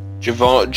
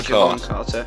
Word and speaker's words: Jevon Carter: Uh, Klay Thompson with 19 points and KD with 21 for the Grizzlies, Justin Holiday with 22, Jevon, I think that's that's how you say it, Jevon Carter. – Javon, Javon, Javon Carter.